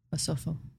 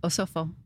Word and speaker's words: Osofo: Osofo. – Say what? – Osofo. – Osofo. – Yeah, that's how you say it in Twi.